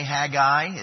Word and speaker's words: Haggai: Haggai, or you could say Haggai is also another way of pronouncing it.